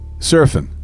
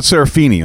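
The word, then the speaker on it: Seraphine: You work with David Coverdale, Eddie Jobson, Chicago founder Danny Serafine. Is that how you say it? – Serafine. – Thank you. I always wondered. – It's not Serafini, I'll tell you that.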